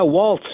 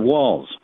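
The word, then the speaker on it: Walz: Yeah, Waltz instead of Walls. – Yes, what was that about? There's no T in the man's name.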